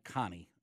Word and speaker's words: Conni: By the way, Jason, it's Mike Connie, just so you know, you pronounce it correctly. So C-O-N-N-I, yes.